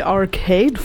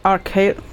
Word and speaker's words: Arcade Fire: Ich glaube hier in Deutschland sagt man immer Arcade. Nee, was sagt man in Deutschland? Hast du schon Arcade Fire gehört? Und im englischen Sprachraum sagt man The Arcade Fire. – Ach, die Betonung.